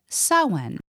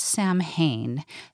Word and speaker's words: Samhain: The wheel begins with Samhain. Not Samhain. That's how it's spelled, S-A-M-H-A-I-N, but it's pronounced Samhain, and it's the witch's new year, the 3-day festival starting on the night of October 31st, All Hallows' Eve.